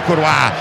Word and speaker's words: Croix: Deu cross, deu Croix, deu Croix, deu Croix. É francês, é a pronúncia francesa que realizou, me ensinou.